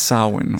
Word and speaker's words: Samhain: And it's pronounced... – It's Samhain. – Okay. Samhain, all right. – Well, fuck. I've been saying it wrong for years.